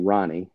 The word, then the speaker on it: Rahne: Yes, that's spelled, or it's pronounced Ronnie, and it's spelled R-A-H-N-E.